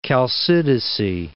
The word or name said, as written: Chalcidice